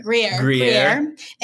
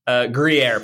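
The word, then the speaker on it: Gruyère: Mozzarella, gorgonzola, feta, Gruyere. What is it? – Gruyere. – And Gouda. – Gruyere, please.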